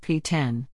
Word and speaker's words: PX: The X is a Roman numeral, so it's pronounced P10.